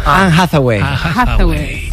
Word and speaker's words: Anne Hathaway: Anne Hathaway.